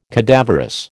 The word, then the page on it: cadaverous: American English